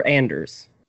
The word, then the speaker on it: Anders: Anders or Anders? – The way it's pronounced in Sweden is Anders, but you know, unless you're born in Sweden, that's hard.